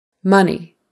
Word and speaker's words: money: Money, money. Always the emphasis is on the first syllable for money. Money, money, money. That O is really a U, a short U sound. We don't say money. We don't use a long O. We use a short U. Money, money. That EY is like a long E, money.